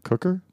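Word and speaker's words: kucker: Is that saying it properly? – It doesn't have any accents on it. It's K-U-C-K-E-R.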